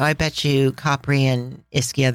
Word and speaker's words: Ischia: And this is spelled I-S-C-H-I-A. Also with the pronunciation on the first syllable, Ischia.